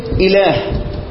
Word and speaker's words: إله: كلمة إله لو أنا بنطق أنا بعد اللام المفروض أحط ألف أنا بقول إله ما بقولش اللام ورا الهاء مباشرة برغم من كده إحنا بنكتبش الإيه ما بنكتبش الإيه الألف تمام.